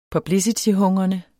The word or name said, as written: publicityhungrende